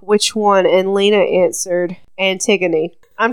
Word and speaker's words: Antigone: I said it right, but I think I looked at it, and I was like, is this Antigone?